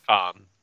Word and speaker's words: Cannes: Is it Khan? How do you pronounce that? – Yeah, Khan. – Khan, like fucking Star Trek?